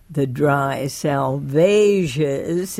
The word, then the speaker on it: Salvages: Interestingly, Burnt Norton, which you just heard, East Coker, The Dry Salvages, is that the correct pronunciation? – That's it.